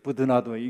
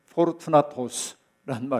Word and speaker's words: 포르투나토스: 그 다음에 여러분 스테바나는 어, 또 어떤 사람과 더불어 소개가 되고 있냐면 부드나도라고 말하는데 이게 참 어, 원어의 발음과는 영 다른 발음이죠. 부드나도 이게 중국식 발음인데 이게 사실은 뭐냐면 포르투나토스란 말인데 여러분 f o r 하는 말과 관련돼요.